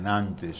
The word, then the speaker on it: Nantes: De Nantes.